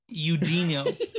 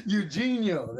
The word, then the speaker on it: Eugenio: Eugenio. – Eugenio, there it is.